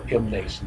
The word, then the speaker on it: Mnason: Some of the disciples from Caesarea accompanied us and brought us to the home of Mnason, or M. Mnason, if you want to pronounce it that way, where we were to stay.